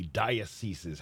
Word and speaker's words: diocese: I heard from somebody who works in one of the dioceses. How do you say that? – Diocese. – Yes. – Yeah, it's hard to say. It's like Onassis.